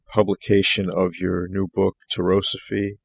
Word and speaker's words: Tarosophy: Am I pronouncing that correctly? I just want to make sure. – I believe so. It's it is a generated word. It's also a trademarked word. And the story behind its generation is interesting. We might cover that later. However, it could be pronounced Tarosophy or Tarosophy, depending on which side of the Atlantic you may be.